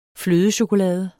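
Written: FLØDECHOKOLADE

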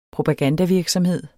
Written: propagandavirksomhed

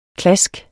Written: klask

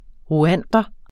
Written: rwander

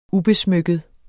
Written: ubesmykket

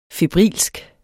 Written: febrilsk

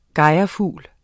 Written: gejrfugl

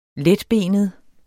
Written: letbenet

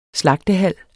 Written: slagtehal

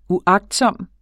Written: uagtsom

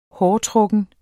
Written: hårtrukken

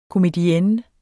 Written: komedienne

